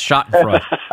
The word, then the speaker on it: Schadenfreude: Just, what's that German, Schadenfreude, is that how you say it? – Schadenfreude, as a, my with my last name, I could help you out here, Chad, it's Schadenfreude.